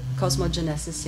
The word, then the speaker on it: cosmogenesis: Um, then we have um, uh, cosmogenes uh, co cosmogenesis, cosmogenesis, am I pronouncing it right? Cosmogenesis, yes, uh, which is a discussion of the.